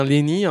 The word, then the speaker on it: Lenny: Alors j'ai pas écorché ton nom, c'est bien Lenny, hein, on est d'accord, hein, tu t'habilles bien comme ça ? – Oui, c'est ça, oui.